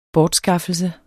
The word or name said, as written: bortskaffelse